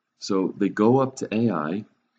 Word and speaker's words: Ai: They go to the next town, a little place called Ai, spelled Ai and pronounced Ai, unless you have the British version, which is Ai.